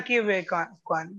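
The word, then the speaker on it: Ke Huy Quan: No, yes, he was the child actor in his name is Ki Hui Kwan. I don't know if I'm pronouncing it. – Yeah, Ki Hui Kwan.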